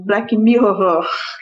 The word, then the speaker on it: Black Mirror: Eu perguntei para a Laurinha assim, Laura, como que eu pre, como que pre, como que pronuncia Black Mirror? Porque o Black eu sei, né? Mas o Mirror. E ela falou assim, ah, se eu tiver alguma dificuldade, você vai e assim, Black Mirror.